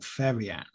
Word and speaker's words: Farian: So the group was actually created by German record producer Frank Farian, or Farian, F-A-R-I-A-N.